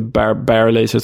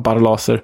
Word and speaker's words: Barlaser: De tar ju alltså ledningen precis före paus och trots att Dan Barlaser, som jag hörde att det tydligen uttalas, inte Bar Barlaser som vi sagt, utan Barlaser, kvitterar